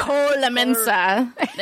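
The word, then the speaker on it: Koelnmesse: It's just Colemesse. I thought because you'd been there, you knew how to say it. – I know how to say Cologne, Germany. That's that's good. That's a start. Colemesse. – Yeah, let's go with Colemesse.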